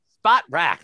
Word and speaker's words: Spotrac: They, if you go to the cap sites, right, is it Spotrac? Sport Rack? I never know how to say it. – Spot Rack.